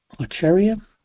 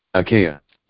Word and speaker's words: Achaia: Acheria? – Achaia.